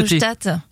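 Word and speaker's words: Hochstatt: Hochstadt, H-O-C-H-S-T-A-T. – Ah oui, non, Hochstadt.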